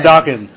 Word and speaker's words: Dakin: Remember Dakin. – Like we were educated on this. It's pronounced Dakin.